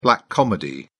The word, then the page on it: black comedy: British English